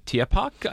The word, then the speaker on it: Tierpark: Now, on arrival in Hamburg, the trio, so Bonnie, Girano, and Dorandera, they're set to perform at the Tierpark, I think I pronounced that correctly.